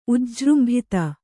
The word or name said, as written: ಉಜ್ಜೃಂಭಿತ